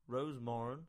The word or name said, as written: Rosemorran